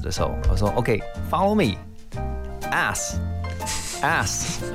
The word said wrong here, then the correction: S: 然后后来我就看到那个台下的老师脸皱起来，然后我就说，哎，奇怪，我发音有错吗？后来老师说，Are you saying ass？应该是 S 对不对？